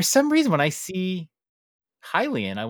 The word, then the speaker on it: Hylian: I have been told that I am mispronouncing the word Hylian. – You are. – By pronouncing it Helian.